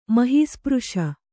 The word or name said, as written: ಮಹೀಸ್ಪøಶ